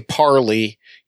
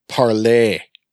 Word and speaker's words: parley: that's why it's called parlay, although it's spelled like barley. – Yeah. – So you don't say parley, you say parlay.